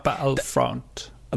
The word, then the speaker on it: Battlefront: Nei, men det var mer en, en sånn Battlefield-mod, kan du si, mens uh, Det uttales altså, Battlefront. Battle, battlefront. battlefront.